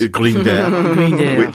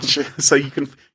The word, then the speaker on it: Grindr: Grindr. – Grindr.